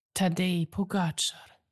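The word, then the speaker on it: Tadej Pogačar: Tadej Pogacar.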